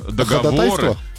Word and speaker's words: договоры: Ну да, слово «договоры». – Ходатайство. – Ходатайство. – Ходатайство. Ходатайство. – Да, правильно, ходатайство. – Ага, как и договоры, например.